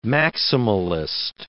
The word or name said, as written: maximalist